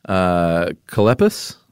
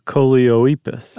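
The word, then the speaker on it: Choloepus: There are the two-toed Colepus. – I was going Colepus? With Coleoepus. – Oh. – Coleoepus. Coleoepus. – Coleoepus? – It rhymes with Bocephus.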